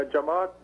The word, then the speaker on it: Jamaat: Yeah, Jamaat, J-A-M-A-A-T, Jamaat, Jamaat is Arabic word for congregation or class, like classroom. Mominin, M-U-M-I-N, Momin, singular.